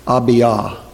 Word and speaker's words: Abijah: In the 18th year of the reign of Jeroboam, Abijah, in reality that is probably pronounced something like Abiah.